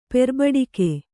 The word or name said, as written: ಪೆರ್ಬಡಿಕೆ